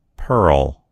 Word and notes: pearl: American English

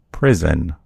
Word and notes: prison: American English

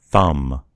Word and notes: thumb: American English

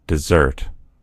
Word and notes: dessert: American English

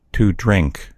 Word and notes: to drink: American English

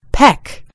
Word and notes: peck: British English

